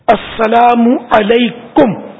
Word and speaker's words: السلام علیکم: السلام علیکم, یہ درست تلفظ, السلام علیکم.